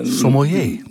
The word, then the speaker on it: sommelier: Spreek je dat uit als bottelier, hè? Dus naar analogie van het oude woord bottelier, iemand die wijn bottelt bijvoorbeeld, of inderdaad op zijn Frans... – Sommelier. – Sommelier, precies. – Juist. – Maar er zat nog iets anders wat me toen opviel, is dat het woord sommelier tegenwoordig ook vaak of regelmatig als sommelier wordt uitgesproken.